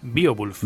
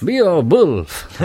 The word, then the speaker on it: Beowulf: Beowulf. – Beowulf.